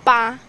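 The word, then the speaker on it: ba (八): Eight in Chinese pronounced as ba, and it sounds like fa.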